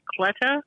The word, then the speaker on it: Cleta: Did you see, speaking of CNN, that attorney, that I don't know if I'm pronouncing her first name right, Cleta? – Yeah, Cleta.